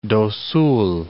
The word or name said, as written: Mato Grosso do Sul